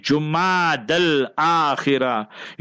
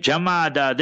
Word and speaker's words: Jumada al-Akhira: When you study what the Arabic linguists say, then the best way to pronounce this current month is Jumad al-Akhira. You will see our masjid board, sometimes even our ulama, and even in their writing, they write Jamada. That's totally wrong. It's Jumada with a Dhamma, with a Pesh on the Jim.